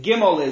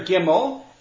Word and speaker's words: Gimel: Another difference, the letters Gimel and Ayin among Ashkenazim will be pronounced like I just did. Gimel is G, Ayin is A. Whereas in Yemenites and some Sephardim, they pronounce them as guttural sounds out of the throat. So it's ga. And the ayin is ga.